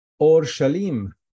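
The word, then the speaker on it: Or-Shlem (ܐܘܪܫܠܡ): So Yerushalayim in Hebrew, Or-Shlem in Aramaic, and then I'm going to pronounce for you in Arabic. Or-Shlem, Aleph, Waw, Ra, Shin, Lam, Yud, Mim. Or-Shlem.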